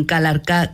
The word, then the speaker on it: Calarcá: Bueno, física, astrofísica, investigadora colombiana, nacida en Calarcá, Quindío, ¿lo pronuncio bien? – Perfectamente.